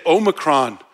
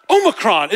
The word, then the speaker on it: Omicron: And I'm like, it's not Omicron, it's Omicron, all right? Because there's 2 O's in Greek. There's a short O, like when we say ah, like I'm going to the office, right? And there's long O's, like O, like oh, look, right? All right, look. All right, and so whenever I hear people say Omicron, I'm like, no, it's Omicron.